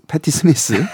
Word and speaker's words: 패티 스미스: 70년대부터 이제 존 레넌이라든지 뭐 브루스 스프링스틴 또 뭐 패티 페이 패티 페이 패티 스미스.